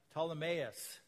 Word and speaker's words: Ptolemais: We continued on our voyage from Tyre and landed at Ptolemais. You don't pronounce the P in Ptolemais.